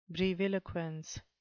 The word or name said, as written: breviloquence